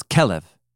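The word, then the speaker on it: Kelev (כָּלֵב): Oh, not with an A. It's Kelev. – Kelev. – Kelev. Yeah. – Why are you laughing? – It's the Hebrew word for dog. – Really? – Kelev is the Hebrew word for dog.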